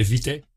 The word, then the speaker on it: Vitae: Bom, na verdade é Vité. – Muito bem. – A palavra vem do latim, é uma palavra do latim, e lê-se exatamente como pronuncia em latim.